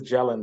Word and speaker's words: Gelin: I think the last name is Gelin, though.